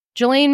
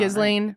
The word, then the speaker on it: Ghislaine: I mean, dude, he was like Ghislaine Maxwell. What's her name? – Yeah, I can never say her name. Gives a shit about Ghislaine.